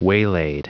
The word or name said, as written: waylaid